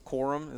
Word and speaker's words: Corum: You don't want to see, like, Blake Corum.